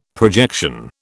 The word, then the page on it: projection: British English